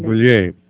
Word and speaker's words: Brulette: But please hold your calls because we have with us right now on the line, Carol Brulette. Is that the correct pronunciation of your name, Carol? – Actually, it's Brulette. It's a French-Canadian name. – Brulette.